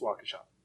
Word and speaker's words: Waukesha: No, it's Waukesha.